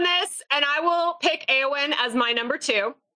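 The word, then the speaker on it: Éowyn: Eowyn? – Yes, thank you.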